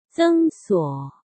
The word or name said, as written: Zeng Suo